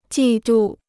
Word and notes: 季度: jì dù

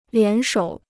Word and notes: 联手: lián shǒu

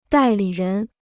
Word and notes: 代理人: dài lǐ rén

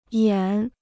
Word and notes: 演: yǎn

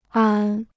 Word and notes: 安: ān

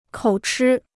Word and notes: 口吃: kǒu chī

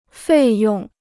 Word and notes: 费用: fèi yòng

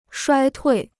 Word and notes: 衰退: shuāi tuì

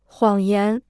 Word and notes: 谎言: huǎng yán

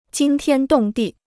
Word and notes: 惊天动地: jīng tiān dòng dì

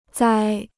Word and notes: 栽: zāi

